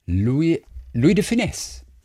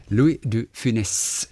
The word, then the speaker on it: Louis de Funès: Louis de Funès. – Aussprechen, okay. Louis de Funès. – Louis de Funès. – Ja, sehr gut. – Ja, sehr schön. Louis de Funès, scharfes S.